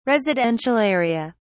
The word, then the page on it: residential area: British English